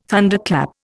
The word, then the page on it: Thunderclap: Indian